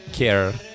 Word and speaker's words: Ker: The band that we're going to hear now is called Care. It's spelled K-E-R, the key K-E-R collective from Germany, with their newly released EP, Buried at Sea / Comfort the Blind.